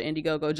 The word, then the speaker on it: Indiegogo: Indiegogo.